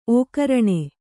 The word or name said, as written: ಓಕರಣೆ